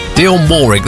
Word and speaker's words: Dionne Warwick: Dionne Warwick.